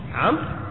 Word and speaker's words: عمرو: بالواو، ولا تنطق، لا يقال عمرو، هذا خطأ، عمرو ليس عمرو، كما ينطق بعض الناس يقول جامع عمرو ابن العاص، هذا خطأ، عمرو هو عمرو ليس عمرو، إنما الواو فقط للتمييز للتفريق بين عمر وإيش وعمرو، وإلا فهو واحد في الحقيقة من حيث النطق، ما في واو عمر عمرو، ما في واو، فالواو فقط للتمييز والتفريق. لذلك يحتاج العلماء إلى الضبط في الحروف كما فعل الحافظ ها هنا، فقال هو بفتح العين وسكون الميم، أي حتى لا تقرأه عمر.